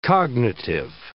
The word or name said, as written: cognitive